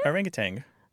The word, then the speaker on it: orangutan: I grew up saying it incorrectly. – Did you? – Orangutan. As if there was a G at the end.